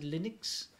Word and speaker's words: Linux: I'm Linus Torvalds, and I pronounce it Linux.